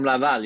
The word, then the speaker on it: Laval: Laval or Lavallois. Is that how you say it? – From Laval, yeah.